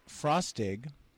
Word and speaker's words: Frostig: If you guys want to know Ryan's last name, it's Frostig. – Like frosting without the N? – Rhymes with frost pig or tossed pig.